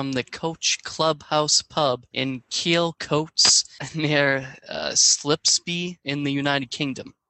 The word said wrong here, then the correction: Spilsby: Spilsby? – Yeah. What did I say? – Spilsby. You said Slipsby.